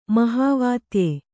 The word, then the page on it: ಮಹಾವಾತ್ಯೆ: kannada